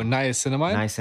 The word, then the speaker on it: niacinamide: The niacinamide. – Oh, niacinamide? – Niacinamide.